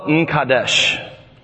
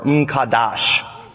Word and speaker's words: M'Kadesh: Jehovah M'Kadesh, or M'Kadash.